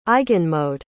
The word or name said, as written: eigenmode